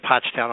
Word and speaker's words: Pottstown: Well, the Mercury of Pottstown, am I pronouncing that correctly, Pennsylvania? – Pottstown, yeah.